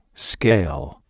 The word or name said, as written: scale